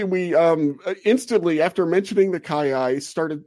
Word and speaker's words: kiyi: Is it the Kiyi? The Kiyi? What was it, Titus? – That is the Kiyi.